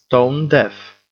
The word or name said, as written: stone-deaf